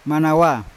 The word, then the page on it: manawa ko: Yawa